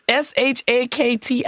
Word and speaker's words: Shakti: You ain't even saying her name right. I hope she come strike you down. – It's Shakti. I do my Shakti. – You did okay.